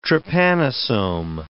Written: trypanosome

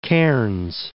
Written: Cairns

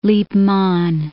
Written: Lippmann

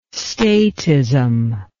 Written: statism

